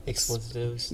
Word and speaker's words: expletives: And then they were having a meeting about her because she used a few explicitives. How do you say that? – expletives.